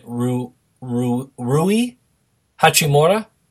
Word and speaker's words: Rui Hachimura: So the two players are, and I'm going to butcher his name, but is it Rui Hachimura? – Sure.